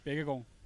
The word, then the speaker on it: Baekkegard: Baekkegard.